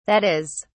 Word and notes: that is: British English

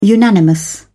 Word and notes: unanimous: British English

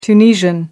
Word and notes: tunisian: American English

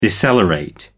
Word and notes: decelerate: American English

